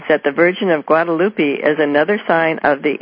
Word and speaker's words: Guadalupe: Yeah, Guadalupe, yeah.